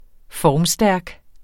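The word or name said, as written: FORMSTÆRK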